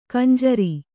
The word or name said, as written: ಖಂಜರಿ